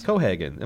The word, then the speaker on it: Cohagen: Cohagen. Am I saying Copenhagen? – You are.